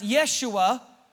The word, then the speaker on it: Yeshua: Because Joshua, interestingly enough, in Hebrew is pronounced Yeshua, which is translated Jesus.